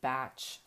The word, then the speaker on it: Bach: Have you ever heard of Bach flower remedies? I guess I'm really supposed to call them batch flower remedies, but I can't force myself to pronounce it that way.